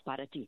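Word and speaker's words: para ti: I go, pot of tea. When I started saying that, then I knew the pronunciation correctly in Spanish, para-ti.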